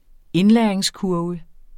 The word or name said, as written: indlæringskurve